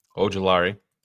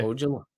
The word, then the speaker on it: Ojulari: Ojulari. BJ? – Ojulari.